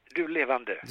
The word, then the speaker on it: Du Levande: What is it in Swedish? Du Levande? – Yes, Du Levande. – Du Levande. We like to pronounce these things correctly here at Film.